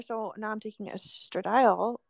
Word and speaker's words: estradiol: Which I always want to pronounce estradiol. I don't know why it's mm-hmm. estradiol, whatever.